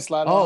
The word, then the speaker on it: Slidell: Did I say that right, Coach? – That's right, Slidell High.